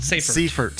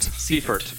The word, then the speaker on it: Seifert: For the writer, Mr. What's his, pronounce his name for me, please. – Brandon King. – Seifert. – Seifert. – Like okay. Siegfried Seifert.